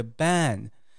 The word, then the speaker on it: ban: 啊，注意一下哦，网络上造谣之后道歉的是 ban，b-e-n，ban 哈，发音不一样哦，是比较松软的这个 ban。那禁止是蝴蝶音的 ban，好，你的舌根会压一下 ban，b-a-n。